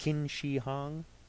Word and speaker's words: Qin Shi Huang: Qin Shi Huang. Did I get that close? Okay, good.